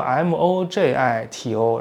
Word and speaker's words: mojito: 莫吉托，其实那个它拼作 MOJITO，然后中文翻译成莫吉托，是因为土鳖不懂西班牙语怎么发音。那 J 应该念喝，西班牙语念喝，所以应该念 Mojito，应该翻译成莫西托比较好。那周杰伦不有首歌什么莫吉托什么的吗？那个他念的就对，就念 Mojito。